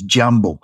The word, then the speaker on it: jambo: So it's like, the word for hello in Swahili is jambo. So I wrote it down as JAM-BOW. Okay, jambo. That's how to say it.